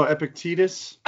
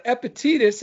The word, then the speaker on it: Epictetus: Oh, you pronounced it correctly. – I've heard you say it before. That's how I knew it. – I forgot how I said it before, but Epictetus is correct.